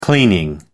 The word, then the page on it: cleaning: American English